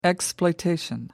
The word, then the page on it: exploitation: American English